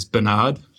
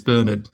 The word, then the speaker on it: Bernard: The Americans do not know of the pronunciation Bernard. Huh. They pronounce Bernard as Bernard. – Yes. – And I think the same applies to Gerard as well, whereas the British and Irish pronunciation is Bernard.